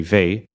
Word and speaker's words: Weil: And a famous French mathematician, maybe one of the greatest mathematicians of the 20th century, André Weil, it's spelled with an L, but I learned recently you don't pronounce the L in the end.